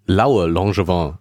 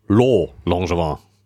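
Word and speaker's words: Laue-Langevin: Und äh, deswegen hätte ich das auf jeden Fall immer äh, Laue Langevin ausgesprochen. – Ja. Und die Franzosen sagen aber natürlich Lo Langevin.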